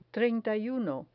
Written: treinta y uno

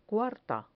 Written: cuarta

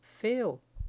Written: feo

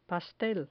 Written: pastel

